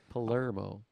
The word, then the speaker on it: Palermo: Anything you think is right, just Palermo. Switch it, because it's going to be wrong. Palermo.